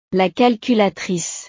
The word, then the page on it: la calculatrice: frances